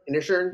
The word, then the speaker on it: Inisherin: How do you pronounce that? Inisherin? – Yep. More or less, that's how I do.